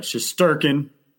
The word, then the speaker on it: Shesterkin: The Shesterkin, that one, I sometimes I mess up, but Shesterkin got to be a little more, pronounced on the T there.